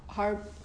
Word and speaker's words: Harbater: But the rabbi of the family gave a hesped, and he was speaking about Rabbi Harbater, am I saying? – Harbater. – The emphasis is on the first syllable.